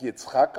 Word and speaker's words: Yitzhak: Auf Hebräisch spricht man den Namen Isaak Yitzhak aus.